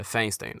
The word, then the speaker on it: Feinstein: Segundo, mesmo assim, olha só, até alguns democratas foram lá e exigiram explicações, tipo a senadora californiana Diane Feinstein, é, Feinstein, né, pronunciando em inglês.